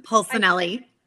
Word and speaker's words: Polsinelli: Polsinelli.